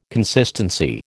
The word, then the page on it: consistency: American English